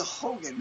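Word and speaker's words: houngan: It's a Hogan, not a Hugen. That N is silent.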